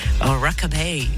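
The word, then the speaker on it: Rockabye: Rockabay, oui je l'ai bien prononcé, ça arrive après Dua Lipa.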